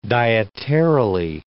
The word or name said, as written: dietarily